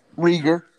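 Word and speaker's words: Reagor: Oh, they still got Jalen Rigar. Rigar. I don't think I said his name right. Rieger. Uh, Rieger.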